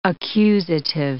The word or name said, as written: accusative